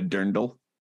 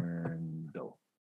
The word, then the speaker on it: Dirndl: Dirndl. Yeah, that's it. Dirndl. – Dirndl. Spell it, please. – D-I-R-N-D-L.